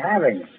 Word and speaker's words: having: Don't say having some fun. Say having. Pronounce the G. – All right, all right. G, we were to having some fun.